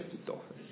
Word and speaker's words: Achitofel: Achitofel, capitolo 17, ricordate Achitofel, si legge Achitofel, sì.